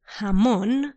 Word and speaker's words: jamón: So then if you want to say ham, in Spanish it's called jamón, but you need to try to do it slightly stronger. Jamón. Jamón. If you're unable to do it as strong as that, don't worry, because if you pronounce it as an H, it's fine. People will understand you.